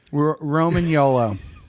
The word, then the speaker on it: Romagnolo: R-O-M-A-G-N-O-L-O. Yeah. – Oh, Romagnolo. – Romagnolo. There's no N there, though. – You only Romagnolo once. – It's Romagnolo. – I know, but that's how they pronounce that in Italian.